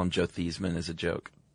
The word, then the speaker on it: Theismann: By the way, you know, Joe Theismann. I don't know if this is true. Supposedly his name was pronounced Theismann until college, really? – And he changed it to rhyme with Heisman in a push for the trophy.